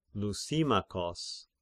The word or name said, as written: Lysimachos